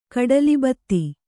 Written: ಕಡಲಿಬತ್ತಿ

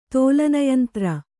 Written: ತೋಲನಯಂತ್ರ

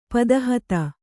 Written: ಪದಹತಿ

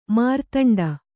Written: ಮೇರುದಂಡ

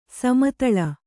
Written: ಸಮತಳ

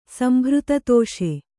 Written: ಸಂಭೃತತೋಷ

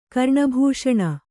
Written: ಕರ್ಣಭೂಷಣ

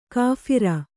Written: ಕಾಫಿರ

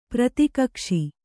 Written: ಪ್ರತಿಕಕ್ಷಿ

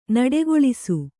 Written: ನಿಟ್ಟೆಗೊಳಿಸು

